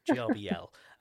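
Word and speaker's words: Grbl: You said that your machines mainly run Gerbil? Gerbil? How's it pronounced? – Gerbil, Jarbil, whatever people call it, Jarbil.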